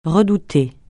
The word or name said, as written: redouter